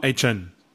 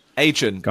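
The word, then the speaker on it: Achane: Ich glaube, also keine Agent Chain. Dann, ach, ich weiß es nicht. Wie, wie, der Agent, nur ohne T. So kann man es, glaube ich, ganz gut. Agent.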